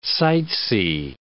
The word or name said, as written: sightsee